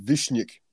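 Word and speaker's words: Višnjić: T-I-N? – Yep. – Ooh. – Oh, his last name is pronounced Vishnik.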